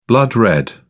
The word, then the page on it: blood-red: British English